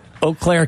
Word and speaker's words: Eau Claire: Is it Eau Claire? Is that how you pronounce it? – Eau Claire? – No, it's Eau Claire.